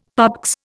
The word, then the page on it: PABX: Indian